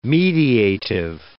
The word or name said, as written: mediative